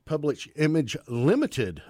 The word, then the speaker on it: Public Image Limited: We heard Public Image Limit, of course. Public Image Limited, of course. I lost a syllable there somewhere.